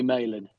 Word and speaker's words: Malin: It's actually Malin.